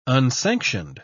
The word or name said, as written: unsanctioned